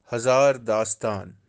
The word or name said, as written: Hazaar-dastaan